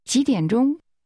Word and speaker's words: 几点钟: That's pronounced with a rising tone, followed by falling, rising, and high-level tones. Ask again, at what time? – 几点钟? – Here's the answer, 1 o'clock.